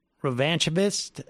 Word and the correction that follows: revanchist: am I saying that right? Revanchist, the casual revanchist, you know, what to think, but needs to be done in such a way that it's like it is spoon-fed and pre-digested.